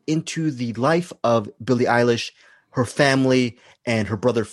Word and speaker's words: Eilish: Billie Eilish, right? – Yes, correct. Eilish, yes.